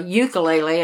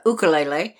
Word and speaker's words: ukulele: I have to say it correctly since I've lived in Hawaii. It's not a ukulele. It's a ukulele.